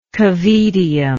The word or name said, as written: cavaedium